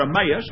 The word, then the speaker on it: Emmaus: and he's walking down that road to Emmaus, or Emmaus, it's pronounced both ways,